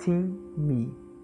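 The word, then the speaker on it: tim -me (τιμή): A palavra grega para honra é time. – Pronuncia-se tim -me.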